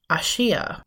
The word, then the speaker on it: Asia: She pronounces her name as Ashia, which friends sometimes abbreviate to like Aussie.